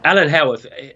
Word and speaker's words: Howarth: Is it Alan Howarth? Is that how you say his name? – Well, that's right, Alan Howarth. He's got a lot to answer for.